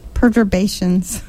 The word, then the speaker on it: perturbation: can be influenced by minor perturbations. – You know, a butterfly flapped, and that's why you are mispronouncing that word. – It's a perturbation.